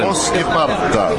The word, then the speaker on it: Poskiparta: Yeah, Finnish language is totally phonetical. So everything you see, you pronounce it exactly how it's written. – Poskiparta.